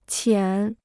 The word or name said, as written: 浅